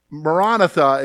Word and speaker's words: Maranatha: Maranatha is the way you would pronounce it, and it means come, Lord Jesus, come.